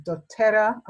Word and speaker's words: doTERRA: So Sarita, tell us, what are batch flower remedies and doTERRA? I don't know if I pronounced it correctly. – It's beautiful. The way you say it is beautiful.